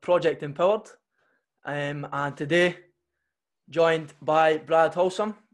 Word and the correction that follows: Holtzman: uh, Holtzman, sorry. Is that saying that right? Aye.